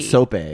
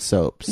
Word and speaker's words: sopes: Someone said you pronounce it sope, not soaps.